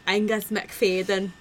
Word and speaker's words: Angus MacFadyen: who was in Braveheart with Angus MacFadyen. That's how you pronounce it in his native language, Michelle.